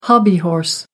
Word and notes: hobbyhorse: American English

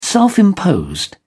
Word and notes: self-imposed: British English

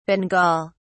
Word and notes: bengal: British English

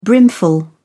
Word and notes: brimful: British English

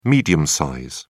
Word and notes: medium-size: British English

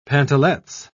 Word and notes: pantalets: British English

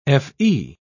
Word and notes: fe: British English

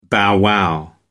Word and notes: bow wow: American English

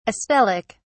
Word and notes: astelic: British English

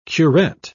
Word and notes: curet: British English

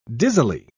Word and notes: dizzily: British English

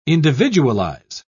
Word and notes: individualize: British English

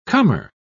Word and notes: comer: British English

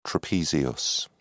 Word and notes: trapezius: British English

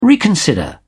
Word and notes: reconsider: British English

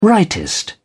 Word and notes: rightist: British English

